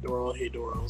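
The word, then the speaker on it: Dorohedoro: Is it Doro Hidoro? – Hedoro. Someone told me to watch it, and they called it Doro Hedoro, so I'm going to say that.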